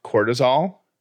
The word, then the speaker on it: cortisol: They are measuring the level of cortisol. Am I saying that right? Cortisol? – I think so.